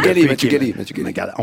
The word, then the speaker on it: Gallet: Mathieu Gallet. – On prononce toutes les lettres en français.